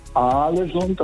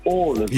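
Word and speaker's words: Ålesund: Haaland, und zwei A's werden als O ausgesprochen und ein A mit einem Kringel oben drauf ist eigentlich auch eine Aussprache, ein O. Also das heißt nicht Aalesund, das heißt Aalesund.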